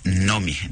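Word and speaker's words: Noomi: En sueco se pronuncia Nomi, Nomi Rapaz.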